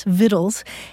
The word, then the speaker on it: victuals: And so they put the letter C back into it and started spelling it V-I-C-T-U-A-L. But the thing is that the old pronunciation was already so well established that people kept pronouncing it victuals anyway. So today, you'll see either the word victual spelled V-I-T-T-L-E or V-I-T-T-L-E-S, victuals, and you'll also see it spelled V-I-C-T-U-A-L-S, victuals, and you look it up in the dictionary and the pronunciation is victuals for both words.